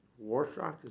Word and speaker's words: Rorschach: My uncle used to say that it's a Rorschach text. Rorschach, is it? – Rorschach.